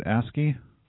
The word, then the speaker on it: ASCII: Soundwave is trying to do some ASCII art. Is that how you say it? ASCII? – Yeah, that's right.